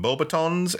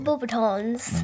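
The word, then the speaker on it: Beauxbatons: Yeah, that's how they would say it. French, maybe, or I'm butchering that pronunciation. But we say Bobatons. That's how the English in Bobaton. This book are saying it is Bobatons. So, although Bobaton is also easier to say.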